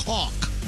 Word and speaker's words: caulk: I liked your emphasis on the L and caulk. – Right. – It's actually silent.